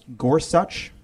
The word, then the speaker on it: Gorsuch: And he did say in his testimony that it's pronounced Gorsuch. That is so frustrating. It's a spondy, right? The emphasis is the same on both the first and second syllable.